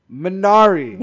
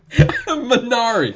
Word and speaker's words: Minari: Minari. – God. – Minari.